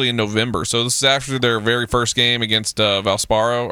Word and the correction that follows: Valparaiso: or how I always say the college name. Valparaiso? – Valparaiso, thank you. I always say it wrong.